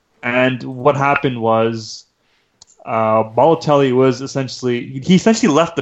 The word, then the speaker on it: Balotelli: I think it's Balotelli. – Balotelli.